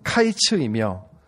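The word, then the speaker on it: 카이츠 (קיץ): 여름 과일의 히브리어는 카이츠이며 끝, 마지막, 종말을 의미하는 단어는 캐츠입니다. 카이츠와 캐츠. 영어가 발음이 비슷함으로 인하여 북이스라엘의 멸망이 임박하였다는 것을 드러내고 있는 환상입니다.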